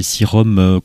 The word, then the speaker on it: Sirom: Les Sirom, comme ça se prononce, S-I-R-O-M, qu'on avait découvert avec un autre incroyable disque qui s'appelait The Liquefied Throne of Simplicity.